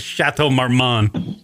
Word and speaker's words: Chateau Marmont: I only want to go there so I can fucking text Kieran that I'm at the Chateau Marmont. God damn it. Hey, what's the name of it? Chateau, am I saying it right? I don't even know. – Yeah, yes. Yes, you are. Chateau Marmont.